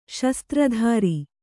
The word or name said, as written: ಶಸ್ತ್ರಧಾರಿ